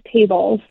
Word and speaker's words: Tablz: And is it pronounced tables? I know it's spelled T-A-B-L-Z. – Yes, it is tables.